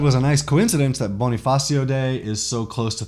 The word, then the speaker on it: Bonifacio: Andres Bonificio. Bonifacio. I think I'm mispronouncing that the whole time.